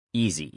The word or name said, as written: easy